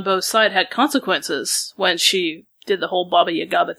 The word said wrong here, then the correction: Baba Yaga: I know I'm saying it wrong. But Baba Yaga thing. I know I added an extra syllable in there.